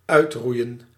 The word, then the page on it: supprimer: France; [sy.pʁi.me]